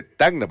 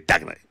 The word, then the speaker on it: Tacna: No estoy diciendo Tacna, no te, me dijeron, no se dice Tacna, se dice Tacna porque es con C, no es con G.